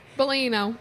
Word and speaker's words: Bellino: Even though John Jansen left her and then went to Alexis Bellini. Bellino, right? – Bellino. – Bellino, and they started dating. And she's, how do I say this? Jesus, J-U-G-S.